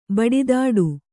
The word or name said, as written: ಬಡಿದಾಟ